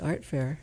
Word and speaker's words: Art Fare: What is Art Fair? – So Art Fair, A-R-T-F-A-R-E, because the spelling or the pronunciation can be confusing.